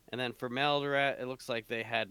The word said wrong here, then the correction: Maladroit: It's Maladroit.